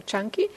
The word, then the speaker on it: Canki: En este discurso específico que se llama Chanki, se escribe C-A-N-K-I, pero se pronuncia Chanki.